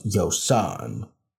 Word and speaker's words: Yosohn: So it's pronounced Y-O-S-O-H-N. So S-O-H-N, like John. So it's like Yo John, but instead of Yo John, it's Yo Son.